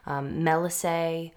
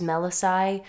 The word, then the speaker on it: Melissae: So sometimes you'll hear Melissae or Melissae, Melissa, Melisses. Melissa, the name for a person, comes from the word for honeybee. So those are just all different pronunciations you might hear for this word. I personally use Melissae. I don't really know why. it just seems to easily roll off my tongue.